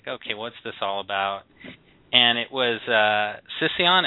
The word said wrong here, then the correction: Cision: Oh, Cision. – See, that's why I'm saying it wrong. Just have to say it right. Cision.